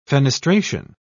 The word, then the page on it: fenestration: British English